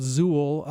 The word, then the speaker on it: Zeuhl: One is called Zool, and no, it's not the demigod from Ghostbusters. It's a different Zool. Z-E-U-H-L is how that's spelled.